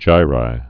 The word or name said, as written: gyri